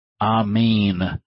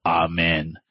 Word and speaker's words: amen: From what I understand, this is the proper pronunciation of that word, amen, like that of a lion's mane, as opposed to amen, which may actually be a purposeful and manipulative, incorrect pronunciation of that term.